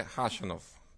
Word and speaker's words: Khachanov: Sì, allora, il giocatore russo mi hanno detto che la K iniziale è muta, per cui la giusta pronuncia è Khachanov.